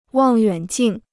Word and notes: 望远镜: wàng yuǎn jìng

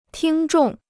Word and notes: 听众: tīng zhòng

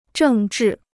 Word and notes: 政治: zhèng zhì

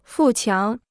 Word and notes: 富强: fù qiáng

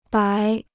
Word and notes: 白: bái

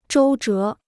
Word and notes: 周折: zhōu zhé

